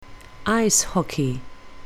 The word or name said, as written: ice hockey